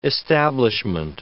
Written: establishment

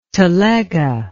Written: telega

